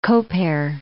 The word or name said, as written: Koper